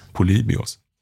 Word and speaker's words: Polybios: Polybios.